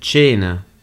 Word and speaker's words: cena: Yeah, so here we've got the C-E spelling, so the CH sound, cena. Let's do a quick recap. How do you say meat in Italian? Can you remember? Can you help us out? – Carne. – And how do you say dinner in Italian? – Cena. – And why is the C pronounced as a CH sound in cena? So it's pronounced as a CH sound because we have the E that follows the C.